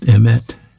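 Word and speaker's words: emet (אמת): But this Hebrew word for truth is pronounced emet, E-M-E-T, and it's not just accurate information, but reliable information, enduring information.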